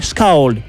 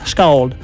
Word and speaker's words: Skald: Autant le groupe chante en vieux norrois, autant s'ils mettent un accent sur le A, ça se prononce AO. Donc je dirais, au risque de me tromper, Skald, avec un petit sifflement dans le S, Skald.